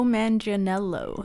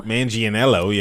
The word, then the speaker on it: Manganiello: Joe Manganiello. – Manganiello, yeah. Manganiello.